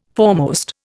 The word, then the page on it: foremost: Indian